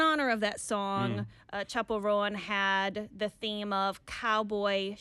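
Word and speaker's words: Chappell Roan: Yes, I looked on social media 네. to listen to the singer's 네. pronunciation, mm. and it's Chapel Rowan.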